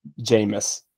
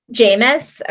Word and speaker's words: Jameis: Jameis. – Jameis, okay, on the bench.